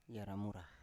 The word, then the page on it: yara mura: Biak